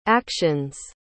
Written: actions

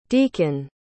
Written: deikun

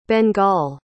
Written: bengal

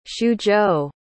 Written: xuzhou

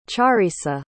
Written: Cha'risa

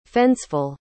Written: fenceful

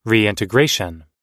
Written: reintegration

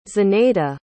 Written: zinaida

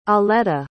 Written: aleta

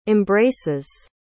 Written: embraces

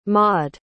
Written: Maude

